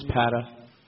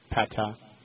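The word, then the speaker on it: Pata: Anyway, before we get back to Miss Christ and all the other stuff there, University of Miami defensive tackle Brian Patah was shot and killed. Am I saying his name right? – I believe it's Patah. Patah.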